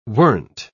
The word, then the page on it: weren't: British English